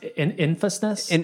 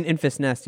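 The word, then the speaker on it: Enfys Nest: I will say the Sky Pirate Gang, it's pronounced Infestness. – Infestness, yeah.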